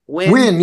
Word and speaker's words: Nguyen: Nguyen, right? – Nguyen. Yeah, that's how you pronounce Nguyen.